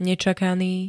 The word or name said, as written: nečakaný